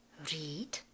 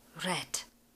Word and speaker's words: read: Sloveso číst, read, je nepravidelné, ale je tak trošku zákeřné, protože ve všech třech tvarech se píše stejně, ale v přítomném tvaru se vyslovuje read a v těch ostatních dvou tvarech red, jako červená barva.